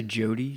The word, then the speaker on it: Jody: It's actually Jody, J-O-D-Y.